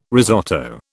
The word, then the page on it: risotto: British English